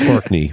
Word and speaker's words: Orkney: Are you sure you're saying it right? – Yeah, I actually went. It's Orkney for sure.